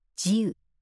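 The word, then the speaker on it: 慈雨: 慈しむ雨」と書いて「自由」と呼ばれ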